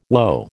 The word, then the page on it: woe: American English